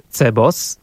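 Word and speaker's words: CBOS: I tak na przykład CEBOS będzie skrótowcem literowo-głoskowym, ponieważ najpierw wymawiamy pojedynczą literę C, a następnie całostkę BOS.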